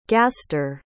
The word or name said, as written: gaster